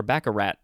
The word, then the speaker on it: baccarat: But yeah, I thought it was baccarat, or baccarat, but it's no T.